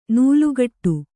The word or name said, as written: ನಾಲುಕಟ್ಟು